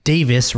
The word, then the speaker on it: Davies: Dave asks, why do British people pronounce it Davis rather than Davies when it comes to Alfonso Davies or like Ben Davies or something like that?